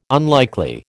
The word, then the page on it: unlikely: American English